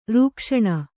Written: ರಕ್ಷಣೆ

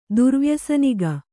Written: ದುರ್ವ್ಯಸನಿಗ

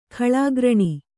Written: ಖಲಕರಣ

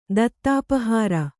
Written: ದತ್ತಾಪಹಾರ

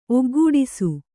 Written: ಒಗ್ಗೂಡಿಸು

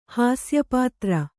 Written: ಹಾಸ್ಯಪಾತ್ರ

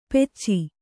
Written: ಪೆಚ್ಚಿ

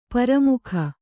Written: ಪರಮುಖ

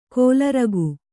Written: ಖಲೂರಿಕೆ

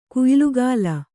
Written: ಕುಯ್ಲುಗಾಲ

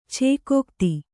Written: ಛೇಕೋಕ್ತಿ

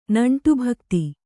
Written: ನಂಟುಭಕ್ತಿ

